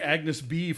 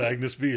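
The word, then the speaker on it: Agnès b.: I've been saying Agnes B for all this time. – From that moment on, I was like, it's not Agnes B, it's Agnes B.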